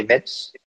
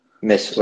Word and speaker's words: Metz: T'as Saint-Étienne, Lorient et Metz. – Ouais, c'est ça. Metz. Metz, ouais. Excuse-moi. Après ça, c'est chacun sa prononciation.